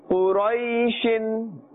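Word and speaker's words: Quraishin (قريش): We are not saying quray, quray is incorrect, quray. Sheen, don't stretch the sound of the sheen and say shin, shin is incorrect. In the normal manner, without putting any stress or pressure or tension on our tongues or mouths, we should pronounce Quraishin, Quraishin.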